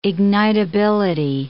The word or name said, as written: ignitability